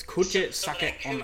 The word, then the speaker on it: Kuchisake-onna: It's how you pronounce it. It's not Kuchisake. – Anna. – It's Kuchisake-onna.